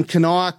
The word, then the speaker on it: Kanawha: There's definitely an extra syllable in the word. – Right. – So if, I don't know if she's saying it, Kenawa, or if she's saying Kenwa. But if you're from Kanawha County, West Virginia.